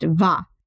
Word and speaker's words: Woh: And I believe it's spelled W-O-H, but I believe it is pronounced Va.